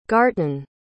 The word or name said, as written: garten